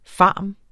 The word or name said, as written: pharm.